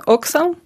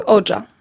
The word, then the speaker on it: Hoxha: Eh, porque si se escribe H-O-X-H-A, pero sí se pronuncia ocha, ¿verdad? – Eh, en francés es oxa y en albanesa se dice oja.